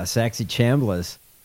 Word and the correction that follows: Saxby Chambliss: I couldn't say his name correctly because it's insane. Saxby Chambliss? – Saxby Chambliss.